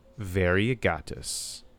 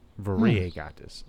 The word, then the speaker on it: Variegatus: So, Galeopteris, Variegatus. Variegatus. The I and the E. I got to remember to pronounce it like it. Like, each vowel has its own, is pronounced in Latin.